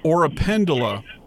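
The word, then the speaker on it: oropendola: Montezuma orangipendula. Am I saying it right? – Or a pendula. – That's it? – Yep.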